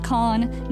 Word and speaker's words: Kahan: The artist's name is Noah Kahn, and his last name is one syllable. It's Noah Kahn, just Kahn, not Kahan, even though it's spelled K-A-H-A-N.